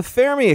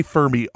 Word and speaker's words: Fermi: There's one not too far from us here in Chicago, mm-hmm, called the Fermi Accelerator. – Yeah. And you said it right. Fermi. I say Fermi all the time. Fermi Lab. It's Fermi Lab.